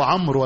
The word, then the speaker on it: عمرو: أولهما عمرو بن خالد شيخ المصنف رحمه الله تعالى، فقال حدثنا عمرو بن خالد هو ابن فروخ ابن سعيد الخزاعي الحراني، وهو بفتح العين، إن البعض حينطقها إيه؟ عمر، وهذا تصحيف، وهذا إيه؟ وهذا تصحيف، فهو عمرو وليس إيه؟ وليس عمر.